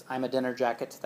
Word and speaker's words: Ahmadinejad: I should forget how to pronounce it. It's Ahmadinejad. That's the mnemonic.